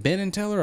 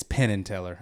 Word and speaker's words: Penn and Teller: Is it Ben and Teller or Penn and Teller? – It's not even ringing a bell for me. – The musician. It's Penn and Teller. I was right.